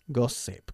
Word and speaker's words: gossip: Gossip, con S, no con sh.